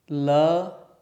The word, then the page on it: l: sanskrit